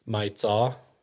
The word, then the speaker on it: Maiv Txos: I actually don't know my aunt's Hmong name, but I'm pretty sure my mom's Hmong name is Mai Tsaw. Spell it M-A-I-V-T-X-O-S.